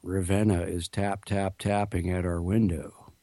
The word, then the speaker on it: Ravenna: Now, is that how you pronounce that, Ravenna, or is it Ravenna? – It's Ravenna.